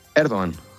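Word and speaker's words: Erdoğan: Veo que pronuncias Erdogan y no como nosotros aquí que llamamos Erdogan. ¿Los turcos dicen Erdogan o Erdogan? – Erdogan. Recep Tayyip Erdogan, sí, sí.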